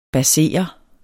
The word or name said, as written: basere